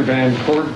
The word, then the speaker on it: Van Court: My name is Steve Baker, B-A-K-E-R, Commissioner of the Board of Prison Terms. – My name is Arthur Van Court, V-A-N, capital C-O-U-R-T, Commissioner, Board of Prison Terms.